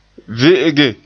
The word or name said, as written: ve'ege